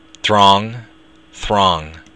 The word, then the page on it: drong: Irish